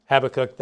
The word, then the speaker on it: Habakkuk: Some of you like Habakkuk. That's the way I learned it way back in Sunday school days. But now I use Habakkuk because that's closer to the Hebrew pronunciation.